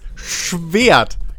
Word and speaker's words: Schwert: Ähm, das ist ein zweischneidiges Schnert oder ein Kompromiss, den ich, ich habe schon wieder falsch gesagt, ich kotze. Es ist ein zweischneidiges Schwert.